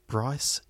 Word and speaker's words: Bryce: My name is Bryce Chambers. – Bryce Chambers. How do you spell that? – B-R-Y-C-E.